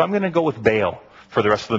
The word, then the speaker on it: Baal: Or, depending on how you pronounce the word, Baal. Or, if you go right back to the original Hebrew, you would pronounce it, and I'm only going to do it once today because it almost hurts, Baal. Because in Hebrew, every consonant has a vowel, and so when you have 2 vowels together, you split up the syllables.